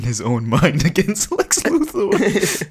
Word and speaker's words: Luthor: And then he starts doing good, and he's like, Lex Luger. And then Lex Luthor's like, Luthor. Luthor. I own the news stations, so please just say it right.